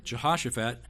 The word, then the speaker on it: Jehoshaphat: Sort of sounds like you sneeze when you say his name. Jehoshaphat, and although he was a godly king, he made a couple of hiccup decisions.